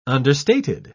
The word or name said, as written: understated